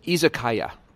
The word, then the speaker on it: izakaya: It's izakaya.